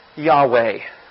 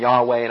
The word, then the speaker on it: Yahweh: So how do you pronounce YHWH? Just you or something. You know, you can't pronounce it without vowels. We need vowels. And so in some instances, we simply give the A-E sound and call them Yahweh.